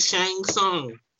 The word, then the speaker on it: Shang Tsung: I said Shang Tsung. That's how you pronounce the man's name.